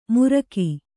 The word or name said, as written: ಮುರ್ಗಿ